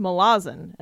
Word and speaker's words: Malazan: He's like, oh yeah, it's pronounced Malazan. And I was like, what? – What? – And he was like, yeah, they're from the Isle of Malaz.